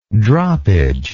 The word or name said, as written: droppage